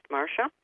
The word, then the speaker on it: Marcia: You can also reach me on Facebook at Marcia McMahon. It's spelled Marcia, but it's pronounced Marcia.